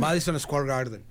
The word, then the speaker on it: Madison Square Garden: Y ahorita, al día de antier, nomás para llevar a colación, Bon Jovi comenzó su gira en el Madison Square Garden, allá, lo dije bien, ¿verdad? – Madison Square Garden.